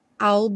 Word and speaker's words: I'll: Já vimos que esse I will, quando contraído, soa como all. I'll be home for Christmas.